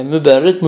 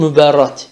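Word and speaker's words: Mubarrad: İmam müberrit, müberrit, müberrat. İki türlü okunuyor o. Sevenleri herhalde müberrit diyormuş. Sevmeyenleri müberrat diyorlarmış herhalde.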